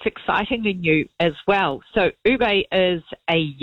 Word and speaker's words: ube: ube, I think it is pronounced.